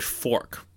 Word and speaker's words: Forque: So what opened in the location after, you know, they took down the sign is an upper-class restaurant named Fork, but it's spelled F-O-R-Q-U-E, not F-O-R-K.